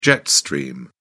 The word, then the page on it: jet stream: British English